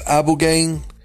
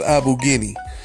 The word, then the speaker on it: Ibogaine: Some people pronounce it as Abu Gain. Some people pronounce it as Abu Guinea.